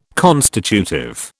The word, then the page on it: constitutive: British English